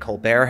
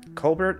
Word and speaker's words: Colbert: You have Matthew Lillard, you have Joseph Gordon-Levitt, Stephen Colbert has, or Colbert, I think, is how it's actually pronounced.